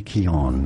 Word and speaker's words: Keoghan: Many say Keoghan. That's not correct at all. Barry Keoghan. Barry Keoghan. And now you know more. – Barry Keoghan is how you pronounce it. It's like the G is silent.